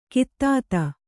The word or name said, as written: ಖಾದಿತ